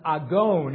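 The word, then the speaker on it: agon (ἀγών): Now, the Greek word underlying this translation is pronounced agon.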